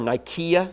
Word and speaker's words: Nicaea: And the Council of Nicaea, or Nicaea is the correct pronunciation of it, that took place in the 300s, did not vote on which books would be counted as authentic and inspired.